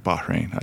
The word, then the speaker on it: Bahrain: He's currently playing in the Bahrain League right now. – I wasn't even aware Bahrain had a league. – I may have pronounced that incorrectly too. – Bahrain, I usually say.